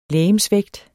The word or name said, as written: legemsvægt